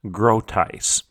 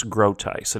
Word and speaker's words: Groothuis: It is titled Fire in the Streets: How You Can Confidently Respond to Incendiary Cultural Topics by Douglas Groteis. That's Douglas Groteis, and it's spelled G-R-O-O-T-H-U-I-S, Douglas Groteis.